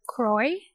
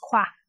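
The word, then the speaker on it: Croix: So my name, a lot of times I hear Croy, but I'm actually also like half French, and my last name is pronounced Qua.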